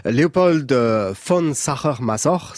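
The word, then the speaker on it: Leopold von Sacher-Masoch: Léopold von Sacher-Masoch, c'est comme ça qu'on prononce exactement.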